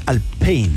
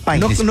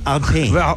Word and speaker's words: Alpine: pero lo dicen mal porque la pronunciación real es más Alpine. No como Alpine. – Claro. – No es Alpine. – No es anglo. – No, es francés, po.